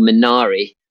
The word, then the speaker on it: Minari: We've got a really great film called Minari, is how it should be pronounced,